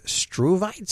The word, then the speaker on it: struvite: had surgery about 4 years ago to remove struvite stones. Am I pronouncing that right, Dr. Debbie? – You got it.